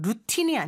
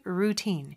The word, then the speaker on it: routine: 자, 발음은 그냥 루틴이 아니죠. routine 이렇게 발음을 하고요.